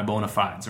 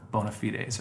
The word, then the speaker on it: bona fides: light to mid uh, weight as well so uh, yeah that's that those are my uh, my my bona fides or my bona fides or however you're supposed to pronounce that I think it's fides. – Is it fides? Yeah. Okay, you know, bona fides.